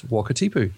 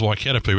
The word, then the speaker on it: Wakatipu: And I wound up doing the edit, and now I'm winding up introducing it because you can't pronounce Wakatipu. – Yeah, well, I always thought it was Waikatapu, but then again, you know, I'm not from New Zealand.